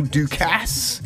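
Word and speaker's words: Daukaus: He will be going up against Kyle Dukasse. I mean, that's how you say it, Kyle Dukasse.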